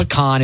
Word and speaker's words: pecan: No, pecan is how you say it.